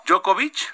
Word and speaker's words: Djokovic: ¿Quién Djokovic? – Tú sí sabes, ¿no? – Djokovic.